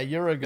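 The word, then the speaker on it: Uragaan: Uragon. Oh wait, yeah, Uragon. – Is it just Uragon? – Oh. Yeah. – It looks way crazier. Okay. – Uragon, and then the other roly guy's Rotobon.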